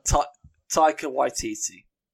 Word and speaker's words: Taika Waititi: how do you say it? I like Thor Ragnarok, you did that right. – Taika Waititi.